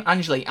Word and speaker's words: Anjli: Doctor just tweeted out that it's uh, uh Ange, Ange, Angel. Is it how you say her name? Anjli. Anjli.